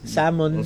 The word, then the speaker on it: salmon: Salmon. – Salmon. Correct pronunciation.